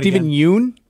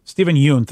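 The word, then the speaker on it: Steven Yeun: And Stephen Wynn. Did I say his name right? – Stephen Yoon. – Stephen Yoon. Thank you.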